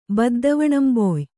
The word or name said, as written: ಬದ್ದವಣಂಬೊಯ್